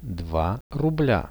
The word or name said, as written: 2 rublia